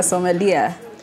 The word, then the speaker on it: sommelier: So how does somebody become a sommelier? – Read a lot of books.